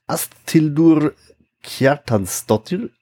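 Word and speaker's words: Ásthildur Kjartansdóttir: Regisseurin ist eine Asthildur Kjartansdottir, oder wie würdest du das aussprechen? – Ja, würde ich auch so sagen.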